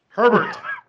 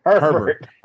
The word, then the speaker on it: Herbert: How do you say his name? – Herbert. – Herbert.